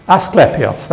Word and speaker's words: Asclepius: Asclepius, can you pronounce that any better than me? Asclepius, thank you.